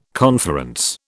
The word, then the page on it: conference: British English